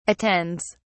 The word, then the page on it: attends: British English